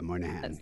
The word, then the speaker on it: Moynihan: Michael Moynihan? – Yeah, Moynihan.